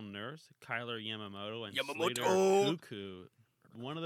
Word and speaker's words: Koekkoek: K-O-E-K-K-O-E-K. – Cuckoo. Cuckoo for Cocoa Puffs. – And I like how the last K is silent.